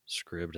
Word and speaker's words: Scribd: Scribd, that's what I've always said. – Okay. – Scribe. – Scribe. I don't know. Yeah, I've never heard it pronounced, so yeah, I'm pronunciation. Scribd sounds like ribbed.